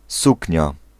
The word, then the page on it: robe: France; [ʁɔb]